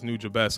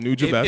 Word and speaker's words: Nujabes: And for those who don't understand Nujabes, and actually I might even be saying his name wrong, because every time I hear Substantial say, he says Nujabes. So I'll just say that now. So Nujabes. – If Substantial says Nujabes, then it's probably Nujabes. – Because he has three syllables.